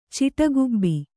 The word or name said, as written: ಚಿಟಗುಬ್ಬಿ